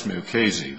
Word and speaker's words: Mukasey: In the first instance, with respect to aggravated felonies, the case, and I apologize if I mispronounce the name, Negev versus Muskie, the issue there... – That's Mukasey.